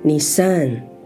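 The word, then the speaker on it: Nisan (נִיסָן): 落，我们就进入了亚比月，这又称为尼散月 Nisan。这个月份的第一天我们称为月朔。亚比月，今年 3 月 22 日的黄昏一直到 4 月 21 日。亚比月在希伯来语称为 Aviv，在和合本常翻译成正月，或称为尼散月。希伯来语发音为 Nisan，是犹太胜利的第一个月份。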